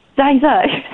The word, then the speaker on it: ZieZie: Je vous aime. Zizi. Zai Zai. Sorry. – Oh, get his name right, mate.